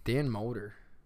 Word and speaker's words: Dan Mulder: Dan Mulder.